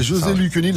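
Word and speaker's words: José Luccioni: Je le prononce bien ou pas ouais, ouais, José Lucioni, la voix française d'Al Pacino, entre autres.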